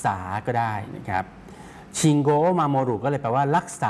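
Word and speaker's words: ชิ ง โ ก (信号): คันจิตัวที่สองออกเสียงว่าโกอุออกเสียงรวบยาวนะครับออกเสียงว่าโกโกอุเนี่ยจะออกเสียงรวบยาวนะครับออกเสียงว่าโกเพราะฉะนั้นคันจิ 2ตัวแรกนะครับเป็น 1คำนะครับออกเสียงว่าชิงโกชิงโกครับ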